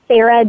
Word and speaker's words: Sara: I'm on Twitter and Snapchat at Sara D.